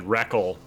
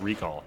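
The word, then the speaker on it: Rekall: Actually, they even said Reckle, and he said, no, it's pronounced Recall in the book.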